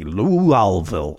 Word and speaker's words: Louisville: Louisville, I believe it's actually pronounced.